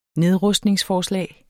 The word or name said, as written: nedrustningsforslag